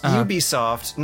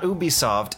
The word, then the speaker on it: Ubisoft: It's pronounced Ubisoft, not Ubisoft. And